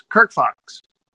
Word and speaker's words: Kirk Fox: It's you put the accent de vu on Kirk. It's Kirk Fox.